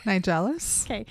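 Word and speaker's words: Nigellus: He may have immediately succeeded Phineas Nigelus. How do you say that word? – Nigelus. – Okay.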